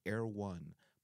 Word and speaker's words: Erewhon: It's called Air One, but it's pronounced E. I mean, sorry, it's spelled E R E W H O N.